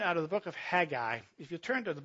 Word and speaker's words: Haggai: And I was told last week, you do the proper way to say it is Haggai.